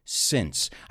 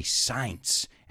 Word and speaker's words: since: Since. I've heard a lot of my students say science, and it's not pronounced science, it's since.